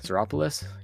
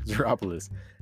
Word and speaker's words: Xeropolis: Xeropolis? Yep, that sounds better. Xeropolis.